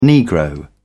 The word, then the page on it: negro: British English